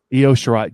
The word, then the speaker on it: Io Shirai: It's Ishira? – Io Shirai.